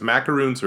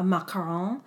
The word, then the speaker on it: macaron: Yeah, macaroons are, well, what I found out though was in this episode, almost everybody called, that's the American way to pronounce it, macaroon. It typically is a coconut cookie, but now it's like, you know, a macaron. But I do feel like they made an intentional choice to call them macaroons in this episode. And even Gordon, most of the judges call it that. There are a couple contestants that use the French pronunciation.